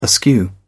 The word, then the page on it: askew: British English